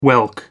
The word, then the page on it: whelk: American English